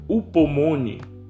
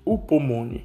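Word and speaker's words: upomone (ὑπομονή): A perseverança, da palavra grega upomone, que se pronuncia H-U-P-O-M-O-N-E, upomone, fala de estabilidade, de constância, tolerância.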